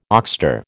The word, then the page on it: oxter: American English